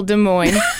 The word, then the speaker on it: Des Moines: Well, there was that one time that I was in that class and I said, I let all those Northeasterners know how to spell Des Moines. So the S is silent.